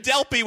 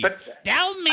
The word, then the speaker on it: Delpy: Wait, even more important, you're going out with Julie Delpy? – That's what he really wants to talk about. – Delpy. – What'd you call her? Delpy with a P. – Delmy.